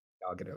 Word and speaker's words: Gal Gadot: How do you say her name? Gal Gadot. – Yeah. Gal Gadot.